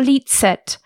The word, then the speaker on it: licet: So sometimes, or when people sing in Latin now, church Latin will go dum licet at the start or licet. But the way I first learned it was licet. So that C pronunciation is not always...